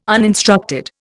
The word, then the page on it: uninstructed: Indian